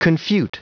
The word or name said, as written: confute